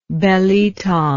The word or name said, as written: belitong